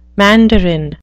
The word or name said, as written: mandarin